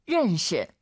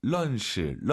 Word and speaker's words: 认识: 认识. – 자, 알 발음 어려우시죠? 런시, 런시. 물론 알아듣잖아요, 선생님. – 是的. – 알 발음 약간 으르, 으르. 혀를 뒤로 보내면서 런시. 아, 이거 쉽지 않아요.